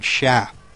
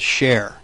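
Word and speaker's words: cher: And, the mispronunciation of the word sha, which is a term of endearment, as share, you know, throughout the movie.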